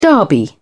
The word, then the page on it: derby: British English